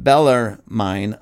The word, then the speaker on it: Bellarmine: Following close behind Francisco Ribera was another brilliant Jesuit scholar, Cardinal Robert Bellarmine of Rome. Hopefully I'm saying his name right. B-E-L-L-A-R-M-I-N-E, Bellarmine of Rome.